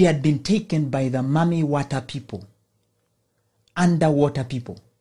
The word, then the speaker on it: Mami Wata: In Ghana, they believe in what is referred to as the Mami Wata people. Mami is spelled M-A-M-I, then Wata, W-A-T-A, the Mami Wata people.